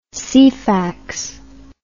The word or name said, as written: ceefax